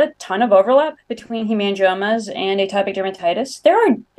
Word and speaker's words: hemangiomas: And can you also explain what hemangiomas are and to let me know if I'm saying it right. – Yeah, you're saying it right. Yeah, so hemangiomas.